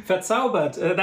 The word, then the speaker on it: Verzaubert: Verzaubert, that's how you say it.